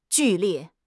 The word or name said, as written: 剧烈